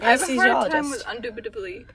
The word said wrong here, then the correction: indubitably: Indubitably? – Indubitably.